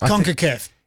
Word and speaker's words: CONCACAF: CONCACAF.